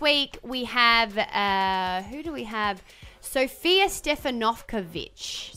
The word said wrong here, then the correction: Stevanovic: Is that how I say her name? – Stefanovic. Stef- no, no St- Stevanovic. Ah, there you go. – Because it's the emphasis that wasn't like Karl Stefanovic's last name, because that's been Aussieified.